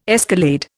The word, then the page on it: escalade: Indian